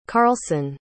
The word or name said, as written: carlson